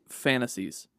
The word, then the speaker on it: Phantastes: It's pronounced Fantasies. It must be an older English word. – Yeah, well, 1858. But, well, 1858. Okay, I guess. Maybe he was using an old English word that was old in 1858.